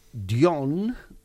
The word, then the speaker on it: Dgohn: i farem una cosa que es diu Dion... però que sí, es pronuncia sí. Com John, però s'escriu D-G-O-H-N, uh-huh.